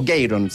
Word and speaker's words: Garon: Y en honor a él, porque el hermano era de nombre Garon, o Gayron, según como se pronuncia en inglés, y por eso como un tributo pusieron con una sola A.